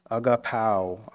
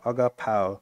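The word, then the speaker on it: agapao (ἀγαπάω): In the original Greek word and the Greek translation, the word that's used in place of love is a Greek word pronounced agapao, agapao.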